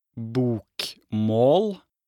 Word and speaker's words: bokmål: The first one is called bokmål, pronounced bokmål.